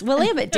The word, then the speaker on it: Willamette: There's a town that doesn't look like, it looks like it's spelled Willamette, but it's Willamette. Willamette. – It's Willamette, damn it. – Yes, Willamette, damn it.